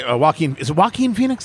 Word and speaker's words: Joaquin Phoenix: Joaquin Phoenix, is that how you pronounce his name? – Yes.